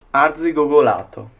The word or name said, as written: arzigogolato